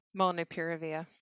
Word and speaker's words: molnupiravir: Molnupiravir.